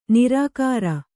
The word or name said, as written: ನಿರಾಕಾರ